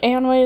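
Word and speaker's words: Eönwë: And his herald, Eonwë, I'm not pronouncing that right, but it's fine. Eonwë also helps move some things along in this chapter. – I think Eonwë is correct.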